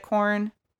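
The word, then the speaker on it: Quorn: I check every time I go near the corn, is that how you say it? Corn, even though it's Q-U? – Yeah.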